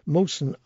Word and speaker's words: Mohsen: His name is Mohsen. I think that's how you pronounce it, M-O-H-S-E-N.